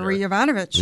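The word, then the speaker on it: Marie Yovanovitch: Marie Ivanovich. – Yeah. – Thank you, because I didn't want to butcher the name. – You perfectly pronounced it.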